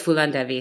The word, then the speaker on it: Phulan Devi: Is it Phulan Devi? Is that how it's pronounced? – Yes. Yes. Phulan Devi.